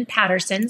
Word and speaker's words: Paterson: And then I'm on Clubhouse with as my name, Shannon Patterson, spelled with one T.